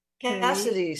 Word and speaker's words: Cassedy: and my name is Ellen Cassidy. – Spelled with an E. – Cassidy, C-A-S-S-E-D-Y.